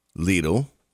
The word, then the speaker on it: Lidl: If you're coming up Staples Mill, you're turning on Hermitage, you are looking for the Lidl? – Lidl. – How do you pronounce that store? – Oh, Lidl. – Very Lidl. Lidl.